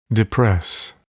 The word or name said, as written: depress